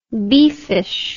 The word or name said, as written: beefish